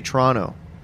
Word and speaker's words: Toronto: How do you pronounce Toronto? Because I've seen that people say the right way to pronounce it is like with a CH. Toronto. – Toronto? – Like, no. That apparently, like, if you live here, if you have a real Toronto accent, you say Toronto.